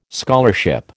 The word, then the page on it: scholarship: American English